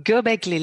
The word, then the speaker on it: Göbekli: Göbekli le hace un umlaut a la, o, porque tiene diéresis.